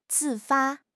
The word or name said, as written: 自发